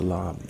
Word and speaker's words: larb: Basically, It's one of those dishes that it's helpful to point to lob when you describe regionality in Thailand, because lob or larb, as it's spelled on English menus in the U.S.